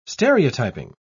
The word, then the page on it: stereotyping: British English